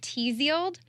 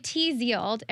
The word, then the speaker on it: Tzield: So if you think of Tzield, okay, the Zield rhymes with shield, and it's shield.